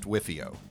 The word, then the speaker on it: WFIO: In startups, there's an acronym, WFIO, pronounced WIFIO.